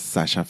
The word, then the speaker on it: Sascha: es Sasha Fitness. S-A-S-C-H-A, Sasha, Sasha fitness, como se escribe, se escribe, la palabra fitness.